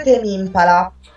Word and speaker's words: Tame Impala: Come li pronunci tu? – Dovrebbe essere Tame Impala.